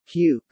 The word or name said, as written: puke